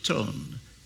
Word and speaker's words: Ton: An old friend from Romania, his name, Joseph Ton, T-O-N, pronounced T-S-O-N.